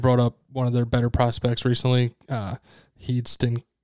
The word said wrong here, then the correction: Heston: Kjerstad. I'm probably butchering your name. Oh, Hest- yeah, Heston Kjerstad.